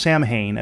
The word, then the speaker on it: Samhain: Now, it's spelled S-A-M-H-A-I-N. It's not pronounced Samhain, as you would in English. It's pronounced Samhain. Samhain. In most of the Gaelic languages, and it has pagan roots.